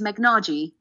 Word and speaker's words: Megnaji: is Megnaji, not easy, a silent H there, and your teacher calls you stupid, you know, you believe it for a very long time.